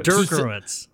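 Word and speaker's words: Drinkwitz: Nope, you didn't get it. – Eli Drinkowitz? – Dierkowitz. – Two syllables. – It's two syllables. – Drinkowitz. – There's no O in the middle.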